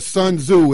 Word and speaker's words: Sun Tzu: Yeah, Sun Tzu. It's spelled S-U-N-T-Z-U.